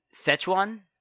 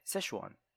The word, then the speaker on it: Szechuan: It's Szechuan. – Szechuan. – Szechuan. I just said it right. Szechuan.